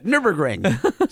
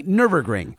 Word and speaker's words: Nürburgring: How about taking a raptor sideways around Nürburgring? We, you had a tough time saying that. – Nürburgring. say it with me. Nürburgring.